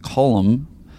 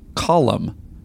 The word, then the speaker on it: Colum: The Irish name Colum is pronounced with two syllables. Colum. Basically, take the name Colin and change the N to an M, just like the word column.